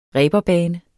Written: reberbane

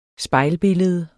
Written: spejlbillede

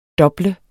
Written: DOBL

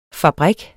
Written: fabrik

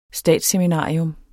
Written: statsseminarium

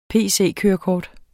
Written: pc-kørekort

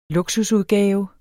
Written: luksusudgave

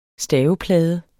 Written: staveplade

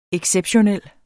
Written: exceptionel

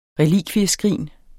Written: relikvieskrin

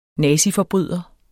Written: naziforbryder

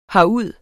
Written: herud

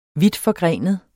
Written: vidtforgrenet

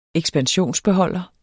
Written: ekspansionsbeholder

